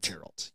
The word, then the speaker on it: Geralt: Yeah, Geralt. – Geralt. – Geralt, yes.